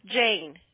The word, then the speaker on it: Jain: J-A-I-N. How would you pronounce her last name? – Yes, Jane. Sima Jane, right? – Yes, she is not in.